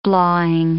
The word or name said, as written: blawing